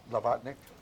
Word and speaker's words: Blavatnik: In 2017, as a fellow, she continued as a fellow of practice at the, how do you say it, Blavatnik? – Blavatnik, yes, School of Government at Oxford, and currently is a fellow of practice at the Institute of Healthcare Improvement.